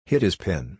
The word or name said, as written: hid his pin